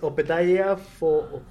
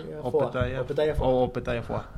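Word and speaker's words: Opetaia Foa'i: No puedo pronunciar de Moana, Opetai Mai Fua. – O es Opetaya Foa. – Opetaya Foa.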